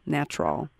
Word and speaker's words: Gnatrol: It's GN, because the G is silent, like it is with Nat. – Just so with Nat. – Yeah, GNATROL, Natrol.